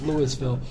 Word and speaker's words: Louisville: But the first one of these we did, there it was down at the Bridges of Hope in Louisville, Georgia, which the rest of the country would pronounce that Louisville, but here in Georgia we pronounce it Louisville.